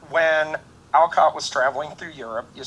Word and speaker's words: Alcott: Sarah and I saw a very interesting documentary on Alcott recently, and by the way, found out in that it's pronounced Alcott.